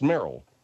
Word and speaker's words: Meryl: Is that Merle? – Merle? – Well, that's the one that they were pointing out to me. – The name's pronounced Merle.